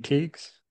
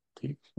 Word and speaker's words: Tiegs: Hey everybody with the Leadership Loading Podcast, and today my guest is MaKenzie Tiegs, right? Tiegs.